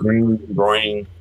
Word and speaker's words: Groening: I'm actually pretty opposite when it comes to Matt Groening, or Grant, however you say his name. I prefer Groening.